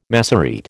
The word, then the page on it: Massorete: American English